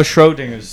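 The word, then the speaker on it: Schrödinger: I have a Schrodinger's Sid Didn't Die. – It's pronounced Schrod. Schrodinger. Schrodinger.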